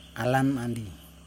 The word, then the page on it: alamandi: Yokoim